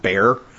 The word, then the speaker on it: Bexar: Considering we live in Bexar County, but everybody pronounces it Bear.